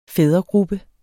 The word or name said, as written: fædregruppe